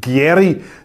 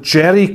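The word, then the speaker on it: Gieri: Der Mann heiße nicht Gary, sondern Jerry Cavelti.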